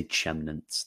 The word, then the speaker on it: Chemnitz: When it comes to Chemnitz, no, I say Chemnitz. That's how the Reform taught me how to say it.